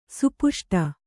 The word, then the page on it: ಸುಪುಷ್ಟ: supuṣṭa